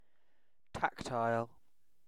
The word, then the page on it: tactile: British English